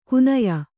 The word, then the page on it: ಕುನಯ: kunaya; Kannada